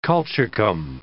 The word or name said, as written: colchicum